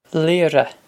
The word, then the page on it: Laoire: Leer-uh